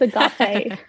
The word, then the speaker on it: agape: No, not agape. Or agape or agape. I'm agape. Teaching them. It's agape.